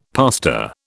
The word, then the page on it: pastor: British English